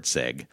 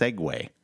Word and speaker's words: segue: And I looked it up, and I'm like, holy shit, Segue is pronounced Segway.